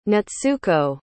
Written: natsuko

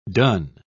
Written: dun

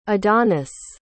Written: adonis

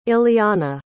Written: illianna

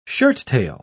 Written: shirttail

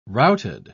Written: routed

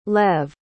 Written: lev

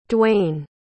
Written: Dwane

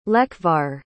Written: lekvar

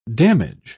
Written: damage

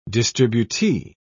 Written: distributee

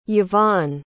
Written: yvon

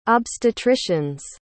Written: obstetrician's

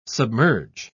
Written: submerge

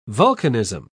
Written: vulcanism

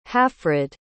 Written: halfrid

